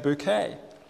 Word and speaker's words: Bucket: She insists on pronouncing it bouquet.